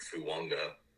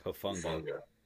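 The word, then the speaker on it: Hufanga: And your one safety, I don't know how to pronounce his name, like Fuanga. – Hufunga.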